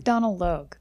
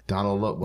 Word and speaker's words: Donald Logue: I think it's Donald Logue. – Donald Logue. – Well, that sounds better. So easy.